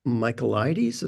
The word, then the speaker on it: Michaelides: The Silent Patient by Alex Michaelides.